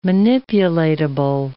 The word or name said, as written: manipulatable